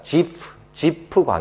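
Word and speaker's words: Ziph: 원래 히브리 말을 영어로 바꾼 거 보면 ZIPH입니다. 지프 광야입니다.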